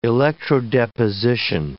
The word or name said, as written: electrodeposition